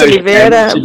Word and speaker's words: Oliviera: Yeah, it's almost there, but it's Oliviera, but it's almost there.